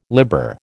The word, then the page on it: libber: American English